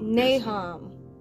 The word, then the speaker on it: Nahum: Okay, so We're going to go to, I cannot pronounce the name of Nahum.